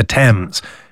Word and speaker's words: Thames: Like if you take a proper name like the Thames, even though it's a TH, it's pronounced as a T, but people tend to pronounce it TH.